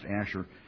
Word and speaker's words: Asher: It says Asher, but it would have been pronounced Asher.